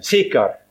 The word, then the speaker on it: Sykar: Så kom han til en by I Samaria som heter Sykar. Det dialektens uttale ordet Sikhem. Sikhem, Sikar, sa samaritanen, Sikar.